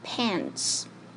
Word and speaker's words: pants: He is wearing gray pants. Pants 一词在美国的口语中是常用词，它可以表示任何一种裤子。发音时注意字母 a 发 a，请把嘴巴张大，跟老师念 a a pants。